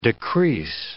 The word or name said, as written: decrease